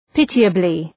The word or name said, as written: pitiably